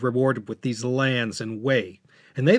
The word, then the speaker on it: Wei: People who have studied Chinese history from this time recognize this Wei state as the one that's spelled W-E-Y in order to differentiate it from the other big Wei of the day, which was a different character but shared the same fourth tone in its pronunciation. Two Weis, same pronunciation, different Chinese character, and different romanization. The other Wei, W-E-I, was located in northeast Henan, spilling over the border into southern Hebei.